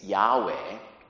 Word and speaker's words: Yahweh: So, no one in ancient biblical world truly knew how to pronounce correctly that four-letter Hebrew word of God, YHWH. Probably you've seen that YHWH, which we all pronounce these days Yahweh.